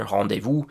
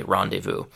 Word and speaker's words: rendezvous: So in French, the pronunciation is super similar, rendezvous. In English, uh, we can't really say that French R, so we just say rendezvous.